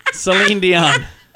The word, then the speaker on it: Celine Dion: Did you know that Celine Dion, is that her name? – Celine Dion.